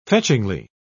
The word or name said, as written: fetchingly